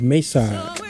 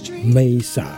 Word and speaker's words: Maysa: Music in the background is the music of Mesa. Yes, it's a song released way back in 1979 by the Crusaders, given a different rendition here by Mesa. Mesa is spelled M-A-Y-S-A.